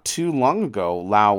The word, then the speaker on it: Laos: On the other side, a more contemporary communist nation that we don't really hear much about anymore, and that's in Southeast Asia, the country of Laos, often pronounced Laos, L-A-O-S.